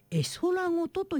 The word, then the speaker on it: 絵空ごと: 新曲これ初めなんて読むのかなと思ったら絵空ごとと読むそうです。